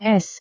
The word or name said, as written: es